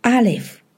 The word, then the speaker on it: Aleph: 希伯来字母第一个字母 Aleph Aleph。今天我们要介绍希伯来的第一个字母。希伯来第一个字母，它的读音是 Aleph，如果以单词的发音，则发音是 A 的音。